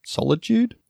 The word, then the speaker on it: solitude: solitude. Did I say that right? – Solitude, yeah. Solitary. – What did I say last time? Solitation? – Solitation. It's like sanitation.